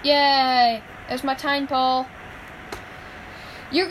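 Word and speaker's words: Tympole: Oh no, I do need like Tynapole, I don't know how you pronounce it. – Yeah, I do have, I have Timpole. – Oh, Timpole, yeah, thanks.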